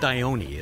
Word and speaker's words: Dione: You had some beautiful images of, it's pronounced Dione, isn't it? – Right, yeah.